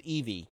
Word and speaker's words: Eevee: Which in Japanese, it's spelled really weird, but I also think it's just pronounced Eevee. I don't know how you would pronounce that. – E-I-E-V-U-I. – Yeah. E-I-E-V-U-I is how you spell it. Yeah, so we're just going to say its Japanese name is Eevee.